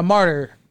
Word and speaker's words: martyr: It's called am I saying it wrong? A Marty? – A martyr.